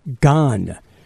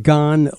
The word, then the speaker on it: Gahan: On the Scoot on the Air Rock Culture calendar, today we wish a very happy birthday to David Gahan. That's how you pronounce his name. David Gahan, lead singer of Depeche Mode, 63 years old today.